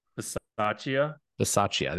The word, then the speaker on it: Bisaccia: Bisaccia.